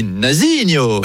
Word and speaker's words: Nazinho: Nazinho, eh oui, Nazinho. – C'est vrai que ça se prononce avec l'accent chantant, Nazinho.